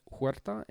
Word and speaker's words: Huerta: My name is Frank Huerta, and you can, that last name is spelled H-U-E-R-T-A.